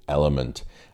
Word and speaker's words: LMNT: Today's podcast is supported by the electrolyte drink company Element. Now it's actually spelt with four letters, L-M-N-T, Element.